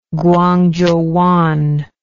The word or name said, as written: Guangzhouwan